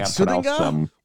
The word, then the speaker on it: Sudeng: Oh, like Sudinga? – What? – Sudinga? – What? – Yeah, there you go. – Sudinga? – Yeah, S-U-D-E-N-G.